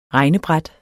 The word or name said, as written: regnebræt